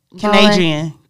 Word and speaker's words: Kenadrian: Okay, so that was Marcus Bowler, or you can find him on Facebook under Kendrian. Did I say that right? – Yeah, Canadrian. – Canadrian. I don't know. He spells it K-E-N-A-D-R-I-A-N Bowler.